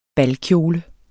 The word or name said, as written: balkjole